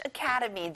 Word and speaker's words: academy: 학원, academy. 캐에 강세를 넣어보세요.